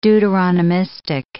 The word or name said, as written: Deuteronomistic